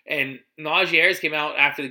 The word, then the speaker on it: Najee: First of all, they call him Najee, not Najee.